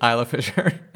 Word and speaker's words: Isla Fisher: Isla Fisher. – Isla? Oh. There's no S? – How do you pronounce island? – Isla. – Okay. – Is it just Isla? – It's Isla.